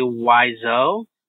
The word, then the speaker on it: Wiseau: Where the Tommy Wiseau. Is that how you say his name? The Room? – That is correct. Yes.